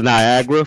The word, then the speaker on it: Niagara: People want to say Nigeria. I'm like, no way possible. He went to school in Niagara from 67 to 70.